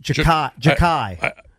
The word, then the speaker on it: Xhekaj: I can't believe there's a human on this planet whose last name is spelled X-H-E-K-A-J. – Yeah, and it's pronounced Jakai.